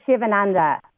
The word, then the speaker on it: Sivananda: Sivananda.